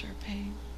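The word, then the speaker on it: Shar Pei: I've only heard it pronounced Sharpay. – Yeah, I think that's correct.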